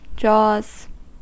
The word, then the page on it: jaws: American English